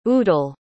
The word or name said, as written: udele